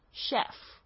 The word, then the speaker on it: chef: Chef，这个呢，这个 ch 也是要发 sh 的声音，不要发成 chef。 Chef 的话，就是这个人英语不太好，所以才会念成 chef。